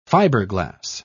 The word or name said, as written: fiberglass